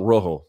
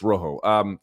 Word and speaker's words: Rojo: All right, third on the list, Kyler Phillips taking on, I'm going to pronounce it like a gringo so I don't, you know, enrage half the audience here. Marcelo Rojo, but it's Rojo, from Argentina.